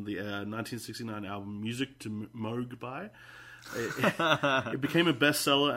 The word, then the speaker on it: Moog: I think it's pronounced Moog.